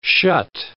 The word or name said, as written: shut